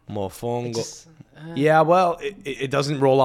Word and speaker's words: mofongo: The emphasis is on a different syllable. Contra ka, as opposed to mofongo, right? – It's more on the second syllable, but you're going to have to make it work.